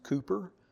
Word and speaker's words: Cowper: We have a little Yorkie, and his name is Cooper, spelled C-O-W-P-E-R, pronounced Cooper, but he was named after William Cooper, who wrote a couple of good hymns.